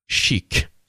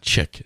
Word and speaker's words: chic: And I thought this was going to come here, but someone says I think it's pronounced chic. – Yes. – Not chick. C-H-I-C.